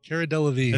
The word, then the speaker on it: Cara Delevingne: Delavigne. Is it Cara Delevingne? – Cara Delevingne. – It's not Delavigne.